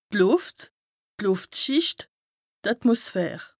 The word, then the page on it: d' Lùft, d' Lùftschìcht, d' Atmosphär: Bas-Rhin; Strasbourg